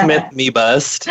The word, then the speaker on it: Smith-Mebust: It's Smith-Mebust.